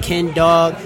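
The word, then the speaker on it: Ken Dawg: So you can find me on my social media for Twitter at Ken Dog. That's spelled D-A-W-G, Ken Dog D-M-F-T. Same thing as on Instagram, Ken Dog D-M-F-T, and on Facebook at Kenny Steele.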